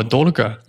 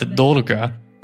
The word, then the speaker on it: долго: Ну, например, слово «дорого», люди думают, что я говорю «дорого». Я не говорю «дорого», это «долго».